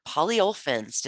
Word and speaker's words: polyolefins: and has several patents in polyolefins.